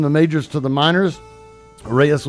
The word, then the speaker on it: Reyes: Turning 28 today is Alex Reyes, and I did pronounce that one correct.